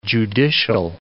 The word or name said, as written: judicial